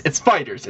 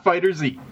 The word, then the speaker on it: FighterZ: You don't pronounce the Z as a Z, even though it's a large Z bigger than all the other text and it's capital at the end. But it's FighterZ, it's not FighterZ.